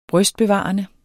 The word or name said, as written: brystbevarende